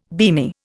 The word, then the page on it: beamy: Indian